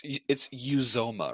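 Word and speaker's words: Uzomah: And it's Uzoma, right? Am I saying that right? – Yeah, I always feel like I'm saying that wrong.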